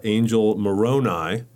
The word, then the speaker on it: Angel Moroni: And then you have this bugle player, and you're going to help me with the pronunciation, but I believe is Angel Moroni. – That's correct.